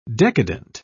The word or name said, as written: decadent